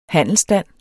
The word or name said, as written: handelsstand